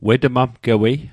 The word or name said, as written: wetmamgewei